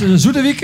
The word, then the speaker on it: Zoetewijk: Dames en heren, de Slow Hands, een band uit Zoetewijk en de omgeving. Zeg ik dat goed? – Ja.